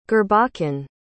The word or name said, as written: gurbachan